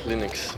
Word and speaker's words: Linux: I pronounce Linux. – 142. – As Linux.